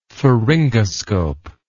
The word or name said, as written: pharyngoscope